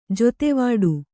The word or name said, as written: ಜೊತೆವಾಡು